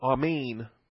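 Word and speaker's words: amen (אָמֵן): The Hebrew word is also amen. It's pronounced just like the Greek, amen.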